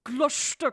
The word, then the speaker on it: Gloucester: Drehort hierfür war die nicht Gloucester, sondern was haben wir gelernt, wie es ausgesprochen wird? Gloucester Cathedral.